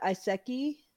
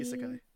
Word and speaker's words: isekai: Uh, Iseki. – Yeah. – How do you pronounce it? Iseki. – Isekai.